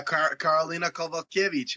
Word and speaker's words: Karolina Kowalkiewicz: Yeah, Karolina Kowalkiewicz.